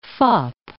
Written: fop

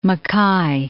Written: MacKaye

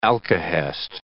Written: alkahest

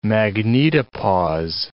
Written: magnetopause

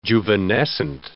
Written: juvenescent

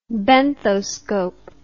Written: benthoscope

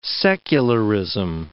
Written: secularism

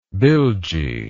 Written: bilgy

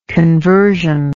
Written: conversionary

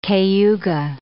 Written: Cayuga

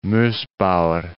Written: Mossbauer

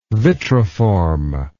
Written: vitriform

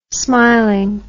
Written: smiling

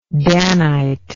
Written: Danite

